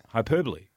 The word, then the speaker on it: hyperbole: Hyperbole. – You can have a hyperbole too, can't you? Isn't that a place or something? – I think the correct pronunciation is hyperbole.